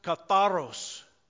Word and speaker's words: katharos: In the Greek language, it's pronounced katharos. Katharos is the word for pure.